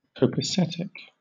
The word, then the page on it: copacetic: British English